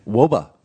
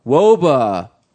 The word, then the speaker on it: wOBA: How do you pronounce the acronym for weighted on base average? – WOBA. – WOBA.